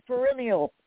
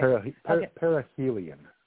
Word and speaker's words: perihelion: That's going to survive its perennial. I said that right. – Yeah, right? – Perihelion.